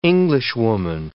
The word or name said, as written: Englishwoman